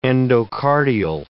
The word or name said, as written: endocardial